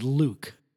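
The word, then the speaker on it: lewk: L-E-W-K. M-V-L. – They pronounce that Luke.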